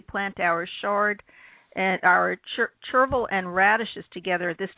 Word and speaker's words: chervil: And then chevrel, I don't know if that's pronounced correctly, which is a companion to radishes for improved chervil. Yeah. – Chervil. And it sounds like a gerbil. So it rhymes. We could create a poem.